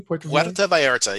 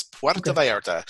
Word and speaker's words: Puerto Vallarta: Puerto Vallarta. Yeah. Just like Puerto Rico, it's Puerto Vallarta.